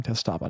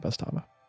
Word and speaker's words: Testaba: I think I said it wrong the first time. Testaba, not Bestaba. T-E-S-T-A-B-A. Testaba.